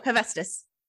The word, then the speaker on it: Hephaestus: Oh, Hephaestus.